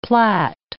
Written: plat

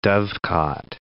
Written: dovecot

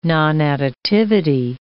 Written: nonadditivity

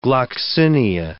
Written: gloxinia

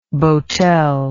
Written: boatel

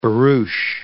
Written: barouche

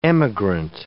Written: emigrant

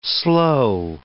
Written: sloe

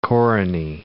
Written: koruny

